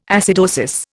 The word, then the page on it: acidosis: Indian